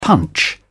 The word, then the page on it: punch in: British English